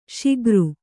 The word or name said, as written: ಶಿಖರಿ